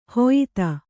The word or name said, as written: ಹೊಯ್ತ